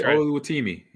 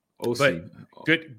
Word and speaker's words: Oluwatimi: I'm not even going to try to say his name, Oluwatimi, but yeah, I'll blow it. – You were right. Oluwatimi. – Oluwatimi.